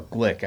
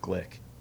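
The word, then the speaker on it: Glück: I like Louise Gluck. I think she's a wonderful poet. Or Glick. I think it's pronounced Glick. G-L-U with an umlaut, C-K.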